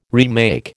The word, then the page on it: remake: American English